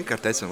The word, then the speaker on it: Kertész: Oui, Kertész, oui.